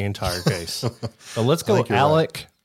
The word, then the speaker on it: Alec: And that's also why you've probably seen the main character's name written as Alex, but down there they pronounce it Alec.